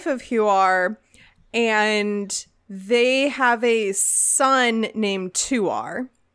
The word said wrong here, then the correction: Tuor: I do believe it is pronounced Huor and Tuor.